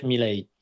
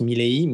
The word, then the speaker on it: Milei: Alors, en espagnol d'Argentine, on prononce Javier Milei. Son nom est d'origine italienne, comme énormément d'Argentins est d'origine italienne. Donc, si on la prononce à l'italienne, ce serait plus Milei, mais étant donné qu'il est bel et bien argentin, c'est Milei.